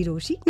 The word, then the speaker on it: Rochie: नाम है मिर्ची रोशी, नाम की स्पेलिंग है ROCHIE.